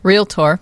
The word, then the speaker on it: Realtor: I find it funny that some guy who can't pronounce Realtor, which, is that the right name? – Realtor. It's two syllables.